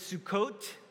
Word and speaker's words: Sukkot: I believe the right pronunciation for our Jewish friends is Sukkot, and the word for tent is Sukkah.